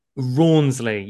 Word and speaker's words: Rawnsley: I get that a lot because I live in France and they mispronounce my name all the time. So I get Ramsay, like Gordon Ramsay. I get Rawnsley. I get all kinds.